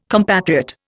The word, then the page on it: compatriot: Indian